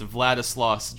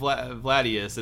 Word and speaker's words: Vladislaus: It's Vladislaus Dragulia. It's not Vladius, it's Vladislaus.